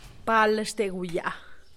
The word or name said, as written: palxteguyaa